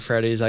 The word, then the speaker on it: Freddie: Got to make your own Five Nights at Freddy's, I guess.